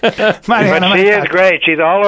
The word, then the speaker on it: Mariana Mazzucato: Many of your readers probably already know about her because it's pronounced Mariana Mazzucato. – Well, there you go.